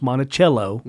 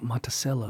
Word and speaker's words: Monticello: People outside of Virginia, it's pronounced Monticello, mm-hmm. – not Monticello. – Monticello. – Yeah, no, it's Monticello.